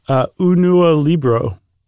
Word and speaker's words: Unua Libro: Unua Libro.